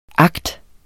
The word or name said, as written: akt